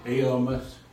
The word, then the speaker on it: Elymas: But E-L-Y-M-A-S. Pronounce it. – Elymas.